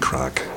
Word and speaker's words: craic: That's always great crack, as we say in Belfast. And I suppose I have to explain that crack isn't crack. crack is spelled C-R-A-I-C. It's an Irish word.